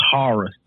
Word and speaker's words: Harris: It's pronounced Horace.